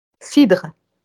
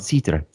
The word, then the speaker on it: cidre: How do you say cider in French? – Cidre. – Oh, okay, that I could do that one. Cidre, there we go.